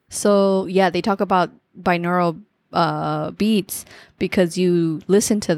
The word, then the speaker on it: binaural: Anyways, so in there it talks about, I think it talks about binaural beats. I don't know if that's how you say it. – Oh yeah, binaural.